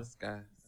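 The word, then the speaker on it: Skai: Is it Shay? – No, Sky.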